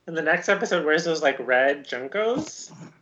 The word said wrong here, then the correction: JNCO: Jinkos? – Whatever. I never wore them, so I never knew how to say them. – Jinkos. – I'm sorry, there's no vowel between those two letters to tell me how to pronounce it. – Jinkos.